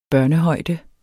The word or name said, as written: foder